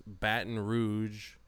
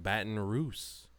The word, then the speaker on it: Baton Rouge: Ronda Rousey thinks Baton Rouge is pronounced Baton Rouse.